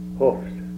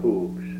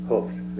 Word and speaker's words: hoofs: Hoofs. Not hooves. Hoofs.